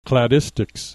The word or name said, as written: cladistics